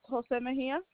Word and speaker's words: Jose Mejia: So it's Jose Mejia. – Spell that first name for me, please. – J-O-S-E.